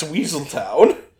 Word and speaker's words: Weselton: That's Weaseltown. Which is a joke that they made in Zootopia, actually, where he played a character named Weaseltown who corrected it when people mispronounced his name as Weselton.